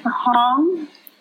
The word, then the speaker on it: Hong: My first name is Hong, H-O-N-G, like Hong Kong. And last name is Zhang, Z-H-A-N-G, Zhang.